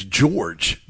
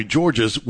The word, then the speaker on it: George: But looks like Stephen Hargis talked to David Gabriel George, DGG, and he said that the correct pronunciation of his last name is George. The S is silent. He said it's George. He said, some people call me Georges, which I don't mind, but if my mom hears it, she might get mad a little bit.